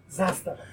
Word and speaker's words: Zastava: How do I pronounce it? – Zastava.